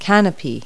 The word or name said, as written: canopy